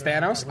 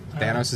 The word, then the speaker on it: Thanos: It is Thanos. I apologize. Thanos is it.